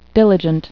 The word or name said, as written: diligent